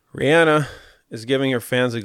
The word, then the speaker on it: Rihanna: Rihanna gives fans a behind the scenes. – Is it Rihanna? – Yes.